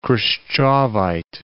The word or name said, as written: Khrushchevite